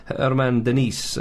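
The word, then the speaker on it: Germán Denis: They've got a striker, Argentinian striker called Herman Denis, up front for Atalanta, but his name is spelt German Dennis.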